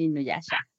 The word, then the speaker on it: Inuyasha: Bueno, la siguiente invitada actriz y directora de doblaje, quien ha prestado su voz a, varios personajes, como Carlitos de Rugrats, a Sango en Inuyasha, si lo dije bien. – Exacto, Sango en Inuyasha.